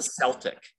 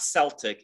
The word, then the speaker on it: Celtic: Fans would go, What exactly is a Laker and what is a Celtic? Why, and for one thing, it's not Celtic, it's Celtic. Okay, let's just be honest about this. Right, you mispronounce this word consistently. It's Celtic, not Celtic.